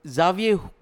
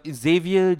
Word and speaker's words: Xavier: So, it's the Swiss way of pronouncing it. – So, the mutant way is Xavier.